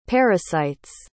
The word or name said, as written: parasites